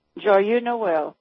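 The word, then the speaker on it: Joyeux Noël: And also, if I'm pronouncing it right, Joyeux Noel. – Joyeux Noel, okay.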